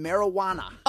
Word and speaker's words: marijuana: The one silent J in the entire English language appears in marijuana.